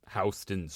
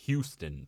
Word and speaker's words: Houston: Houston Street in New York, yes. – Houston, Texas. – No, it's Houston, Texas. – No, it's Houston.